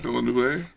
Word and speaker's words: Villeneuve: Do you know Villeneuve? – Villeneuve.